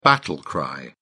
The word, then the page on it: battle cry: British English